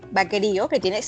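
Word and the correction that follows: Baqueiro: Te voy a decir cómo se pronuncia. Es Aureo Vaqueiro.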